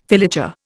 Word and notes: filature: Indian

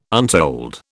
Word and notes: untold: British English

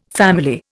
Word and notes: family: Indian

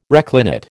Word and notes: reclinate: American English

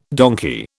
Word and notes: donkey: British English

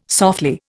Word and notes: softly: Indian

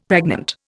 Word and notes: pregnant: Indian

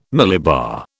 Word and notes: millibar: British English

